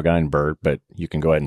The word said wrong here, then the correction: Edinburgh: You have to say Edinburgh, okay? It's like a bra. – Edinburgh.